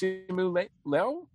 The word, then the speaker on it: Simu Liu: Who I believe is pronounced Simu Liu, Simu Liu, okay. – Simu Liu, this is the first time I've seen him.